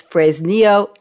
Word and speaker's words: Fresnillo: It's Fresnillo, Zacatecas.